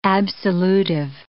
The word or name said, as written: absolutive